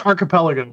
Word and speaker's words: archipelago: And it's Archipelago, by the way. – It's what? – Archipelago. – Oh, the S is silent? Come on. – The second. – Reminds me like Billy Madison. Spell rock. R-O-K? Yeah, it's R-O-C-K. Oh, the C is silent.